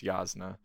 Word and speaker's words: Jasnah: I will cede the pronunciation war, it's Jasnah.